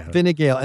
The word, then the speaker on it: Fine Gael: Fine Gael.